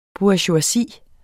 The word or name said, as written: BOURGEOISIER